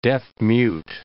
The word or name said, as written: deaf-mute